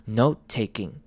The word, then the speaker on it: note-taking: 那连音的方式呢，是把 note 的后面的 te 完全省略掉，然后稍微有一个空格，就是把 t 稍微弱化，所以我们会念成 note taking，note taking，note taking。有没有发现 t 的声音完全不见了？note taking。